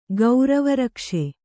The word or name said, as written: ಗೌರವರಕ್ಷೆ